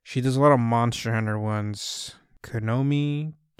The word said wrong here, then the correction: Kamui: Kioni. Cruz will edit it and say it right because he knows which one I'm talking about. – Referring to Kamui Cosplay.